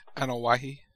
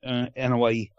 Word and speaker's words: Anoa'i: I keep mispronouncing his last name. – Anoa'i. – Thank you. That's close. Anoa'i.